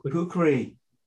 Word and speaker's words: kukri: So can we talk about the do I say it right, the kukri? – Kukri. – The legendary. – In Nepal, we said it's kukri. – Kukri. – Yeah, kukri.